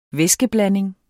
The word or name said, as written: væskeblanding